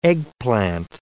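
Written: eggs Benedict

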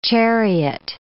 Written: chariot

